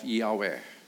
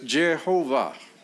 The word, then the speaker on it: Yahweh: When you see capital L O R D in your Bibles, it is the proper name of Yahweh. Y H W H. Others pronounce it Yewah. The Germans pronounce it, pronounce it Jehovah, but it is the name that God introduced himself.